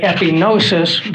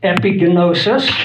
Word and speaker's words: epignosis: We say epignosis, but Greeks don't have silent letters. So epignosis.